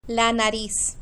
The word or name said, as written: la nariz